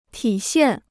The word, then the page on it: 体现: tǐ xiàn